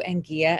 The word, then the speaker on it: Anguilla: So him and his wife, Barbara, moved to Anguilla, the island of, is it Anguilla or Anguilla? It's Anguilla.